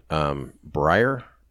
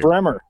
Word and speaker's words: Breyer: Yeah, I guess another thing is these leftist justices, Kagan, Breyer.